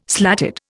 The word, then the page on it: slatted: Indian